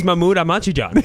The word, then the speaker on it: Mahmoud Ahmadinejad: So in the original Iranian pronunciation... – It is Mahmoud Ahmadinejad. That's exactly how it goes.